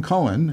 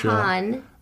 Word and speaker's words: Cohan: No, he was George M. Cohen. – But not C-O-H-E-N. Wasn't it C-O-H-A-N? – A-N. – Yeah, they were C-O-H-E-N. – He pronounced his name George M. Cohen, but he started off in the Four Cohans.